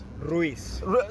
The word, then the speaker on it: Ruiz: Ruiz. – So I've been saying it right the whole time. – Yeah, but that's okay. I mean, you speak in English and like the vowels in English are different.